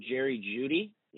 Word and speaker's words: Jeudy: And so, yeah, it goes Burrow, Hurts, Tua, Justin Fields, Jonathan Taylor, Jerry Jeudy.